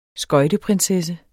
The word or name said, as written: skøjteprinsesse